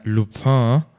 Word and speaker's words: Lupin: Dann könnt ihr Netflix suchten, so wie ich äh, und zwar, ich habe nämlich gestern angefangen, äh, Lupin, ich glaube, so spricht man es aus.